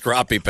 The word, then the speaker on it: crappie: It's pronounced crappie. – Oh, crappie. – That has always bugged me.